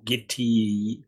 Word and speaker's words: Gitea: Yep, we're using Gitty. Gitty is it called? – Yes, G I T E A, which I'm not quite sure how to pronounce that, but it's Gitty.